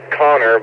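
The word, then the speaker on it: Konnor: My name's Connor, but it's spelled differently.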